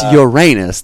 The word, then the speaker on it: Uranus: But, it's Uranus. That is the pronunciation.